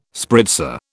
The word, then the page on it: spritzer: British English